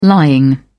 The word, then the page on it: lying: British English